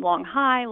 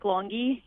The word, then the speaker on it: Longy: So we had a lot of people mispronounce his name. Long High, Long He, everything you can think of. But yes, it is Nick Longy.